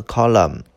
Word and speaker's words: column: 下个单词 column，请注意这个单词的发音读 column，column，column。